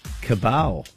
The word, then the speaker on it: cabal: He called them a cabal. Is that how you pronounce it? C-A-B-A-L? – Cabal, yeah.